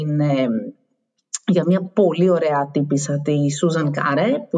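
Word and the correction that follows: Kare: είναι, ε, ελπίζω να το προφέρω και σωστά το όνομα και να μην είναι ναι, αλλά είναι Κάρε πρέπει, να είναι. Πρέπει να είναι Κάρι, Σούζαν Κάρι.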